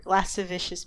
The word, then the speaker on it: lascivious: Wait, how do you say that word? – I think it's lavicious. I think you have it. – Oh, because that's not how it's spelled. Because it's L-A-S-C-I-V. Anyway. Lavicious behavior. Lascivious behavior.